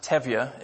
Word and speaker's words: Tevye: So you have, that's pronounced Tevye, if you've never seen Fiddler on the Roof.